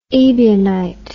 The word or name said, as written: ebionism